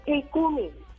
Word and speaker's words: Ikumi: No, ya la van a crear, se va a llamar Ikumi. – ¿Cómo? ¿Cómo se...? – Ikumi.